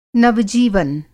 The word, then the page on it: नव-जीवन: Hindi